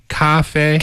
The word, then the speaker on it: 咖啡: It's the tones. – Yeah. – It's a little higher, right? Cafe, yes. – cafe. And it's the same tone for the first syllable and the second syllable.